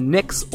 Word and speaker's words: Knicks: New York's NBA team are the Knicks or the Knickerbockers, the K being silent.